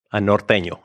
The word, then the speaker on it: norteño: So yeah, there is a switch inside of me that can flip, and I just become a norteño, a Mexican from Chihuahua. – How do you say that again? – Norteño. – Norteño. Notice it's not norteño.